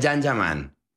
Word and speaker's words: Can Yaman: Se escribe Can Yaman, se pronuncia Jan Yaman.